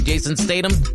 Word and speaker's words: Statham: That's how you say his name, right? Jason Statham.